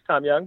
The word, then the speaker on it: Tom Yang: Just to clarify, it is Tom Young, but it is spelled Tomyang, and that's the website.